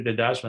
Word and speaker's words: Dedaj: So anyway, my name is Victor Dedaj.